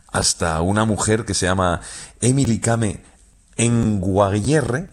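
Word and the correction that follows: Kngwarreye: lo he dicho mal. Emily Kame. – A ver, Emily Kame. – Con Guarreye. – Emily Kame con Guarreye, sí, sí.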